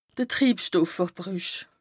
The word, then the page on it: Triibstoffverbrüch: Bas Rhin